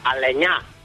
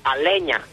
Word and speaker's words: Aleñá: Bueno, pues que Aleñá se pronuncia Aleñá, no Aleña, como dice él.